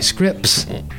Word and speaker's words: Scripps: There's an S at the beginning and the end, but no T, oddly enough. It sounds like Scripps.